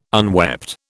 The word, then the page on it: unwept: British English